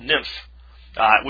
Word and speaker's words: Nipah: For instance, there's a disease called NIMPH, which is spelled N-I-P-A-H.